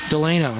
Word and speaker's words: Delano: Delano, that's how you say it.